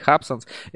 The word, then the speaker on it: Hubsons: Именно ударение на первый слог, правильно говорить, Hubsons.